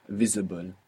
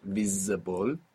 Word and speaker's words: visible: You're not pronouncing that S as a Z, like in English, visible. Many people at home will find themselves doing this, putting that Z there from English and pronouncing visible. We don't want that. We want visible.